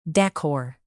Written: decor

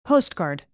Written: postcard

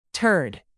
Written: turd

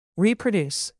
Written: reproduce

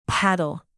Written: paddle

